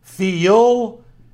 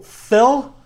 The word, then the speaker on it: Phil: He says, in my Southern Virginia accent, I try to add an extra syllable, feel. He's quick to say that it's one syllable, fell.